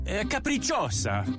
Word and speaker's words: Capricciosa: Try them on the Capricos. Wait, how do you say this? – Capricciosa. It's capricciosa.